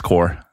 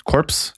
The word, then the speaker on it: Corps: Skrives korps, men jeg har forstått det sånn at det uttales core. – Ja, det også, ofte. Ja, selv om jeg nok I mange episoder har sagt korps.